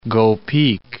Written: gopik

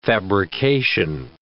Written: fabrication

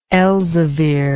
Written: elzevir

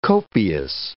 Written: copious

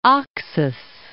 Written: Oxus

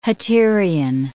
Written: Hutterian

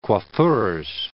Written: coiffeuse

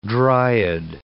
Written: dryad

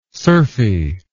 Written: surfy

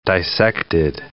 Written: dissected

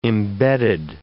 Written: embedded

